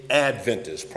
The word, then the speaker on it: Adventist: Seventh Day Adventist, properly spoken. The accent is on the first syllable.